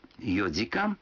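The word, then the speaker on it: йодикан (四時間): Но будьте внимательны. Некоторые цифры в этих случаях произносятся не так, как обычно. 4 часа – это йодикан, 7 часов – ситидикан, а 9 часов – кудикан.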